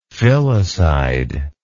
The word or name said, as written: filicidal